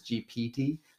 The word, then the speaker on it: GPT: And I have to say it really slowly because I keep saying GTP. – You say it wrong, yeah. – But it's GPT. I think it's because I played GTA.